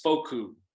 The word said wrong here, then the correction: Fehoko: Fokus. Yeah, I almost said fuck you. – Fehoko. Sorry. Simi Fehoko.